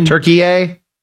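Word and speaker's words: Türkiye: It's supposed to be the new pronunciation. – Are you serious? – Turkey A. For sure.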